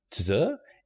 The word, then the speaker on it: Tzur: Yeah, it's Tzur. It's Tzur. Almost okay. like Sir, but with a T.